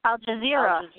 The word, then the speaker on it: Al Jazeera: Al Jazeera.